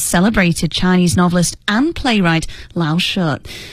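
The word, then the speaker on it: Lao She: The second Laoshe, make sure I'm pronouncing that correctly, Brian.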